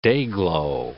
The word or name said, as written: Day-Glo